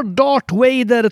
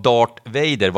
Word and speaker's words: Darth Vader: Men ditt uttal av Darth Vader var extremt försvenskat. – Var det? – Darth Vader.